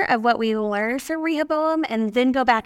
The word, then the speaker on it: Rehoboam: But this week, we talked about Rehoboam. I did go online after yesterday and was like, how to say this name? And they said Rehoboam, like rehab. – Yeah. – And then Oum.